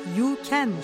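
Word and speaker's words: U Can: İlk ismi olan Uluğ'un baş harfi U ve Can isminin yan yana gelmesiyle İngilizce olarak You Can şeklinde okunur.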